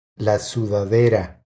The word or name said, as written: La sudadera